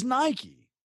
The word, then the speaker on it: Nike: Nike is Nike. That's how Phil Knight, who literally invented the swoosh, that's how he pronounces it. That's how he's always pronounced it.